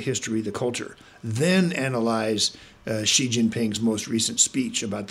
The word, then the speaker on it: Xi: His last name is Xi, President Xi, like S-H-E-E. But a large number of commentators, people with PhDs, generals and admirals who come on TV, they call him G, like Gee Whiz. And there are several other possibilities, because X-I, how do you know how to pronounce that?